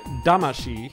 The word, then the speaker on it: Damacy: But it's not spelled like the way it should be pronounced in Japanese, so I understand why. It's kind of hard to pronounce that one. – Yeah. Katamari Damashii,